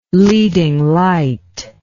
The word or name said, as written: leading light